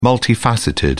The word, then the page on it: multifaceted: British English